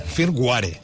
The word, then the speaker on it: firmware: Firmware. Sempre ho diem així tots. Com es té de pronunciar això? – De manera ràpida i entenedora. – Se escribe firmware. – Firmware. Tal com ho diem.